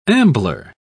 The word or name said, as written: ambler